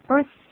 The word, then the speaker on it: birth: 用个 s 来代替，其实这个 th 的音很好发音哦，把舌头吐出来，大概只有半公分就够了啊，对，就好了，birth。 Day 就对了。